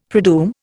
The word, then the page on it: predoom: Indian